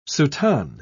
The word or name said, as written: soutane